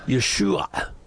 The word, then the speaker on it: Yeshua: which is called ayin. And we don't have that sound in English. – No, no. – It's an a sound. It doesn't exist for us. – Yeah. – So the proper pronunciation would be Yeshua. But again, most Americans can't make that last sound. They can do the SH, not the ayin.